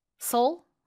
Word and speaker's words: Seoul: 우리가 서울이라고 발음하는 거랑 그냥 원어민이 Seoul. Seoul? How do you say it? – Seoul. – Seoul. – Like the Seoul.